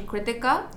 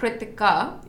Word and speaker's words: Kritika: And there's actually a really soft H in there, but there's no H in the spelling. – Yeah. – So it's like Kritika, because there's a th noise in Hindi, alphanumeric alphabet.